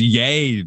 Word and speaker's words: Ye: No, his name's not Ye, dude. It's Yay, dude.